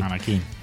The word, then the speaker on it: Anakin: Anakin con acento en la I, Anakin.